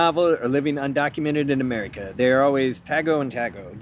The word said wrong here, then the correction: tago ng tago: D- am I pronouncing this right? Uh, tago ng tago was how you'd say it with a full-on Filipino accent, which is hiding and hiding.